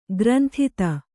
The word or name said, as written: ಕ್ರಂದಿತ